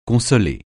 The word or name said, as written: consoler